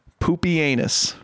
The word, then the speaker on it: Pupienus: And Pupienus.